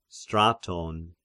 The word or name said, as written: Straton